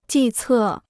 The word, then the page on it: 计策: jì cè